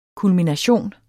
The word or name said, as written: KULMINATIONER